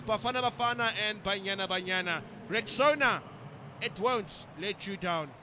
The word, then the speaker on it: Rexona: A friend called it Rexona, but guys, it is Rexona, Rexona. This